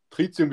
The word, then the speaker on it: Tritium: Tritium wird das ausgesprochen, das Schlaume.